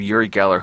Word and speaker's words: Uri Geller: In fact, he's probably the arch nemesis of Uri Geller, who had rose to prominence in the 1970s. I'm probably saying his name wrong, saying it right.